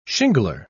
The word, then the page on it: shingler: British English